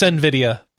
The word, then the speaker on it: Nvidia: It's Nvidia.